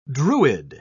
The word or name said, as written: druid